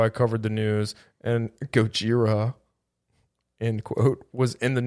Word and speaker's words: Gojira: So it's definitely Gojira, and their new single is Our Time Is Now.